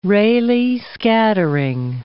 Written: Rayleigh scattering

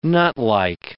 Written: nutlike

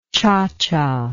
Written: cha-cha

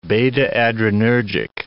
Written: beta-adrenergic

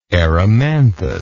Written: erymanthus

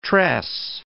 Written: tress

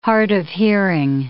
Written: hard of hearing

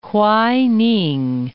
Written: Hwaining

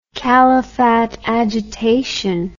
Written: Khalifat agitation

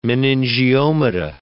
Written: meningiomata

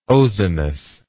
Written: ozonous

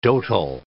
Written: dotal

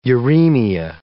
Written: uremia

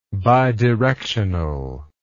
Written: bidirectionally

